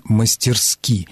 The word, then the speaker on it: мастерски: Действительно, первое, что приходит на ум, это поставить ударение на первом слоге, но орфоэпические словари и словари ударения русского языка единодушно рекомендуют норму с ударением на последнем слоге, именно «мастерски».